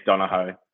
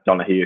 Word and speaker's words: Donoghue: It's to me, it's Donoghue. That's how I've always pronounced it, how it's spelled. But yeah, I mean, you can cut it Donoghue if you'd like.